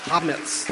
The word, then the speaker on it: Hametz: Hametz. That's what it is. That's how you say it. She thinks I'm funny because I said it, but that's how you say it. Hametz.